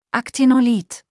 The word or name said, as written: Aktinolith